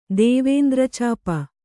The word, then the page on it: ದೇವೇಂದ್ರಚಾಪ: Kannada; dēvēndra cāpa